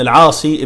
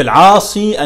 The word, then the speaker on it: العاصي: عمرو بن سعيد ابن العاصي ابن سعيد ابن العاصي ابن أمية القرشي، والصواب في العاصي أن هي تكتب بالياء، تنطق بالياء على كل حال، كما نبه على ذلك علماء الضبط، يعني أن هي لا يقال العاص كما يكتب بعض الناس، يكتبوا العاص كده بالصاد مع الكسر، يعني ده من الخطأ المشهور، وإن الصواب في ذلك أو الأشهر يعني والأضبط أن هو يقال ابن العاصي بالياء.